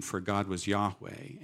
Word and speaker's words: Yahweh: And what scholars think today is this name, Yahweh, is really almost a breath. It's breathing in, yah, and breathing out, way.